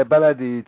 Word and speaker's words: Ballade: And it's, I'm not sure how to pronounce it or what it's about here. It's Ballade. Is that? – Ballade. – Ballade? – Yeah, Ballade.